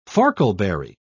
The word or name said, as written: farkleberry